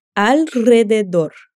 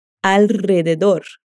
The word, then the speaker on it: alrededor: Vamos praticar sua pronúncia com a palavra que significa por volta de. Repete. Alrededor. Ok, ela confunde nossa língua mesmo. Vamos por partes. Vai repetindo comigo. Dor. De dor. Rededor. Alrededor. Alrededor. Eu vou deixar no material extra o link de um vídeo que te ajuda na pronúncia dessa palavra, tá bom?